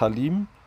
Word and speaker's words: Haleem: Ich würde sagen, man spricht es Halim. H-A-L-E-E-M, so hätte ich es jetzt mal ausgesprochen, Halim, habe ich gegessen.